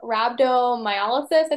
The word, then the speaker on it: rhabdomyolysis: I have rhabdomyolysis, I think is how you say it.